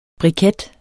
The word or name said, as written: BRIKETTERNES